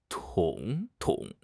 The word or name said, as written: 统统